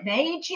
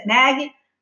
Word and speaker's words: Maagy: Is it Meiji? Is it Maggie? Is it Maggie? Come on, people. It's just Maggie.